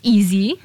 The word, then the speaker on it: EAE.ZIE: Well, the account is called EAE.ZIE, pronounced easy.